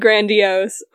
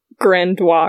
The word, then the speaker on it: grandiose: It was grandiose, if you were wondering. He said grandiose. I always just dropped like the last four letters because if it's French, then that's what you're supposed to do. And that's what I truly believed. I thought it was like, grandois or something. I had never heard it pronounced out loud.